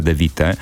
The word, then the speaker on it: De Witte: No i gwiazdą była belgijska producentka i DJ-ka Charlotte De Witte. Właśnie tak należy wymawiać jej nazwisko, De Witte. Jak większość mówi De Witte.